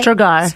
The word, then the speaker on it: Strgar: Strgar, I say that right? Right? – Strgar. – Strgar, sorry.